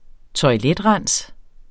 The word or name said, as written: TOILETRENS